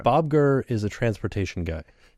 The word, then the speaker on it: Gurr: Bob Gurr. Am I saying that wrong? – No, you're not saying that wrong.